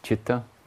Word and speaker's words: citta: Well, even though it sounds like it's C-H, usually it's spelt in Sanskrit, starting to sound like that Sanskrit scholar. Ask me a simple question, I'll give you a complicated answer. C-I-T-T-A.